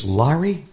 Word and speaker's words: Laurie: I remember her saying, It's Larry. It rhymes with starry.